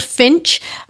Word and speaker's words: Phintch: I think it's online platform, and it's in a version of an app as well, and it's called Finch, but it's spelled with P-H-I-N-T-C-H.